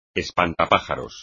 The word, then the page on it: espantapájaros: espagnol